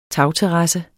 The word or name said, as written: TAGTERRASSE